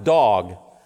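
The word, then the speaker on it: dog (דג): But it's actually the Hebrew word pronounced dog, which isn't a dog, obviously.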